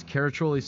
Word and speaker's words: Caraccioli: That's Caraccioli Cellars, C-A-R-A-C-C-I-O-L-I, Cellars, where you have to spell it to drink it.